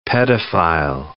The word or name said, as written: pedophile